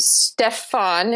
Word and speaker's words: Stefan: So Bandersnatch is about a young programmer named Stefan. Is that the pronunciation we're going with? – Sure. – Sure. – Like the character from SNL, right? That's yes. Yeah, so, exactly. Okay. Exactly like that.